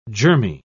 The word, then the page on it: germy: British English